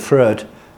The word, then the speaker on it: Freud: Notez bien qu'en anglais, on garde la prononciation allemand. Si vous dites en anglais, what do you think of Freud? Nobody will know what you're talking about. It's it's going to be pronounced Freud.